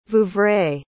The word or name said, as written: Vouvray